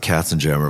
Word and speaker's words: Katzenjammer: Well, we pronounce it Katzenjammer, of course.